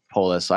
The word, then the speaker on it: Polis: Is it Polis or Polis? – Polis.